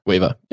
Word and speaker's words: Weaver: Is it Graham Weaver? – Weaver, yep.